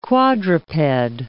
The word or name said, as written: quadruped